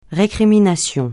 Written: récrimination

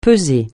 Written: peser

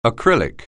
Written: acrylic

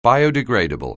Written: biodegradable

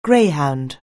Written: greyhound

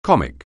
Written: comic

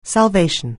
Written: salvation

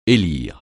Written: élire

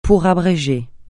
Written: pour abréger

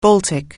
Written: Baltic

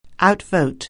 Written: outvote

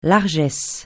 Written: largesse

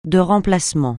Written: de remplacement

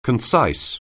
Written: concise

